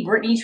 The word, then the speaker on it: Brittany: So it would just be Brittney Trainum, and that's spelled B-R-I-T-T-A-N-Y. I think I'm the only Brittney Trainum in the world to be exact.